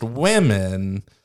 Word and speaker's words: women: I really have to think how to say women, because it's spelled woman, pronounced women, and I feel I get it wrong sometimes. – If it ends in an A-N, it's one woman. – Woman. It starts with an O. You woman. Hit the O hard. – And if it ends in an E-N, you hit the E hard. – Then it's women. Women. – But it's spelled W-O.